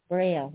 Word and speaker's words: braille: How do you pronounce that? Braille, brow? Braille, eh? In French, braille. In French, it's braille.